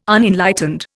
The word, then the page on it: Unenlightened: Indian